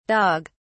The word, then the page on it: dog(s): British English